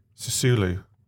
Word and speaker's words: Sassuolo: How is Sassuolo doing at the moment? – Sorry? – Sassuolo. Have I said it wrong? – Sassuolo. – Sassuolo. Oh. Sassuolo.